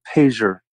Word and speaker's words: Payseur: Well, look up the word pager. It's spelled P-A-Y-S-E-U-R. Pager. Pager family. – How do you spell it again? – P-A-Y.